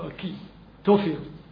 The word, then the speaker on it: Ahithophel: by the name of Ahithophel. or Aki, that is to pronounce it in Hebrew, Aki, Tophel.